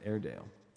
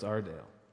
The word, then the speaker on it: Aredale: It's not your Dale, it's not my Dale, it's Ardale, because the town is spelled Aredale. – Uh-huh. – And so it's kind of a pronunciation guide because so many people pronounce it Airedale. – Airedale. – But it's Ardale.